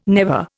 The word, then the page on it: never: Indian